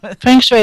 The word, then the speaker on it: feng shui: Okay, and before we get to what feng shui is, I call it, I don't know, feng shui, so... – Feng shui is fine, absolutely fine. Most people say feng shui.